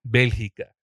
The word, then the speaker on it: Bélgica: Bélgica.